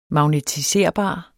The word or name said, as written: magnetiserbar